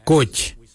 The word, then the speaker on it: goodj: Garli is spelled G-I-L-Y-I, garli. For the traditional stone axe, we say gotch, gotch. Gotch is spelled G-O-O-D-J, gotch.